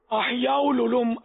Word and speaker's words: احیاء العلوم: آپ نے احیاء العلوم, یہ عام غلط تلفظ ہے. احیاء العلوم عام طور پہ ہمارے کشفی سائیں میں بولتے ہیں.